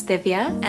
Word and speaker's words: Divya: Hi, my name is Divya, and that's spelled D-I-V-Y-A. – Hi, my name is Yungsi. It's spelled Y-U-N-X-I.